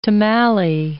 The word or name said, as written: tomalley